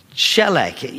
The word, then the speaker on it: tselach: The Hebrew word for prosper is shelech. You spell it T-S-E-L-A-C-H.